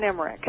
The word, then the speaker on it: Emmerich: Is it with a K or a CH? – It's with a CH, but you said it right. It's Roxanne Emmerich.